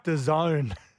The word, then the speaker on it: DAZN: Did you say Darzen? It's the zone. – Well, why is it spelt D-A-Z-N? – That's how it's spelt.